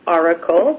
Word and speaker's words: Orykl: So it is Oracle, but it's spelled O-R-Y-K-L.